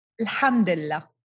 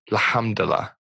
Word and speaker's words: Alhamdulillah: Is it tell me how to pronounce that word, lamdala? – Alhamdulillah. – Alhamdulillah.